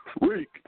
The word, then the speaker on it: Reek: Reek from Too Cool to Con, R-E-E-K. – R-E-E-K? – Yep.